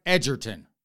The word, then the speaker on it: Egerton: Apparently, I mispronounced Taron Egerton's last name. It is, allegedly, supposedly, possibly, maybe, pronounced Taron Egerton.